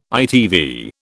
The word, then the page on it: ITV: British English